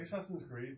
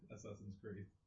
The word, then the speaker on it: Assassin's Creed: It's like Assassin's Creed, but only 50 years old. – Yes, and you pronounced it right. – What? – Assassin's Creed.